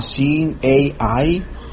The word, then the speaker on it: Seeing AI: Les tenemos una aplicación para eso, se llama Seeing AI.